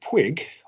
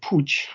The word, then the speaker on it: Puig: But with Albert Puig, or Albert Puig, as we should say his name properly in the Catalan pronunciation, it's a very different kettle of fish.